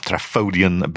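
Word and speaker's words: Trafodion: Now, Travodian, and by the way, it's spelled with an F, Travodian, but they've actually included the Welsh pronunciation to be Travodian.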